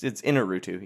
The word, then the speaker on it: Iñárritu: Am I saying that wrong? – Yeah. No. It's Iñárritu.